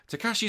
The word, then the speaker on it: Takashi: Apparently, there was a guy called Tokashi Suzuki. Suzuki, sorry, Takashi Suzuki.